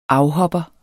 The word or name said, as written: afhopper